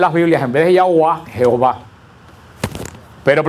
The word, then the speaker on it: Yahvé: La palabra Jehová viene del siglo XI o del siglo XII, que es una mala pronunciación de la Y de Yahuá. Porque en Alemania lo pusieron en una de las traducciones, cuando se estaba escribiendo la Biblia con la fonética alemana, en vez de poner con la Y, la pusieron con una J que suena como Y.